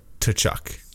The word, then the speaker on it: Tkachuk: Tkachuk.